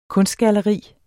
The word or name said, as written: kunstgalleri